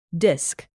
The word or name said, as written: disk